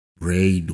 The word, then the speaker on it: RAIN: La semana pasada publiqué aquí mismo una meditación guiada, la meditación RAIN, que es un acrónimo en inglés. Esta meditación Rain o Rain, si se pronunciara directamente en castellano,